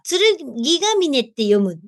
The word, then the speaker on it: 剣ヶ峰: 剣ヶ峰か。剣ヶ峰って読むって合ってる？そこまで行ったの？すごいね。剣ヶ峰って読むんだ。